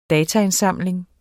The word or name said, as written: dataindsamling